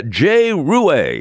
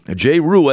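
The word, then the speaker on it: Jay Ruais: And Jay Rue, as I believe that's the way he pronounces his name. I've heard a couple of different pronunciations, but Jay Rue is the mayor-elect in Manchester in a tough battle with Kevin Kavanaugh, a tough one, but a very civil one.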